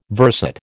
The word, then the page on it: verset: American English